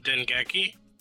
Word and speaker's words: Dengeki: There was an interview done with Hiromichi Tanaka and Koichi Ogawa, who are, of course, members of the development team, on the Dengeki PlayStation Magazine, and they talked about... – Wait. Kudos on the Japanese name pronunciation. – What, Dengeki? – All that stuff.